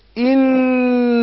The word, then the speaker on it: إن: والإن بتتنطق زي الإي بالإنجليش، إي مش إي، لأن البعض يقول إن جهن إن إي، لا إي دي لا، إي مش إي، يبقى أقول إن جهنم، إن جهنم، إن جهنم، نون مشددة بتاخد غن حركتين، إن جهنم كانت مرصادا، مرصادا.